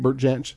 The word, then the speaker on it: Jansch: Am I saying that right, Bert Jansch? – Yeah, that's correct.